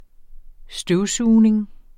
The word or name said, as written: støvsugning